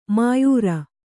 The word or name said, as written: ಮೋಯಿರ